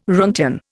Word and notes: roentgen: Indian

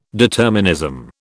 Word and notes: determinism: British English

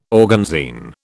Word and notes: organzine: British English

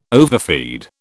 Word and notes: overfeed: British English

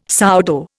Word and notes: sourdough: Indian

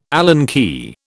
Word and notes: Allen key: British English

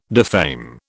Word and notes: defame: British English

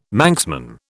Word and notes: Manxman: British English

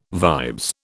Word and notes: vibes: British English

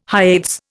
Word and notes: Hyades: Indian

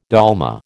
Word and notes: dolma: American English

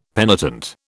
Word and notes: penitent: British English

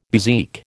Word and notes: bezique: American English

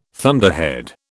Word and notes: thunderhead: British English

